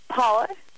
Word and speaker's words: Paula: How do you say your name? – Paula. – Paula. – Oh, Paula. Oh, they started with a T.